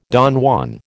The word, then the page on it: Don Juan: American English